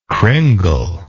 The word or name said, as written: cringle